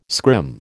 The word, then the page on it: scrim: American English